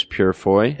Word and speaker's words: Purefoy: Starring James Purefoy.